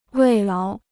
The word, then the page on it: 慰劳: wèi láo